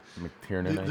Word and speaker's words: McTiernan: McTiernan, pronounce that name? – McTiernan.